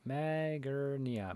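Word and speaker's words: Magearna: Magnera? – The name doesn't even sound familiar. – Magurnia. Magurnia. There you go. I'm not saying that wrong.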